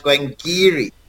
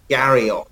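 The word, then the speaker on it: Glen Garioch: So actually, Glengarioch is pronounced Glengeary, as in probably G-E-A-R-Y, Geary, rather than Garioch.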